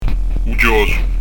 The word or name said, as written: u chjosu